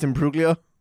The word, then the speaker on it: Imbruglia: It's pronounced Imbruglia.